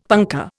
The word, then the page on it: punkah: Indian